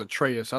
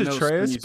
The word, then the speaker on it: Atreus: And his son's name was Atreus. I thought you were talking about the voice. – Yeah. Yeah, it's Atreus, but I didn't know the pronunciation of it.